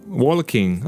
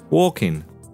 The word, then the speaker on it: walking: Ah, this is a pronunciation issue. Walking. Don't say that little. You don't need to. Walking. Similar ones. Talking. No l pronounced in it. Written, yes. You write the L, the consonant, is there, but not pronounced.